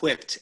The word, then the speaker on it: whipped: Pronounce all the letters. Whipped. – Yeah. – Yes, yeah. They're there for a reason.